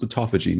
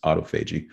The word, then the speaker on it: autophagy: Some people say, no, it's supposed to be pronounced autophagy. No, only in Great Britain do they pronounce it incorrectly like that. It's autophagy. But the key message, Malcolm, is your body is designed to heal.